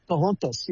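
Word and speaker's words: Torrontés: Torontes is the name of the grape. – Yeah. – Torontes, Torontes, Torontes.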